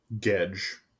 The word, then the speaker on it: Gedge: Gedge. Gedge is the engineer. – Can you spell it? – G-E-D-G-E. Okay. Like hedge with a G.